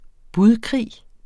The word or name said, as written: budkrig